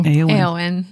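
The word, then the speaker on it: Éowyn: Eowyn.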